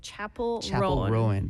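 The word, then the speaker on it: Chappell Roan: Yes, I looked on social media 네. to listen to the singer's 네. pronunciation, and it's Chapel Rowan.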